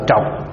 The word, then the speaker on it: trược: Người Bắc đọc là trọc, người Bắc đọc là ngũ trọc. Người Trung hay người Nam đọc là ngũ trược.